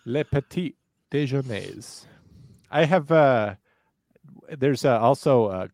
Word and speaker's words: petit: Petite jury. – It. Is this for me? – Yes. – Okay. I believe it's pronounced petit, but I will allow that. P-E-T-I-T space J-U-R-Y.